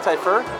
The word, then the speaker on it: Typhur: Typhur? – Yes.